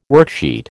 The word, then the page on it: worksheet: American English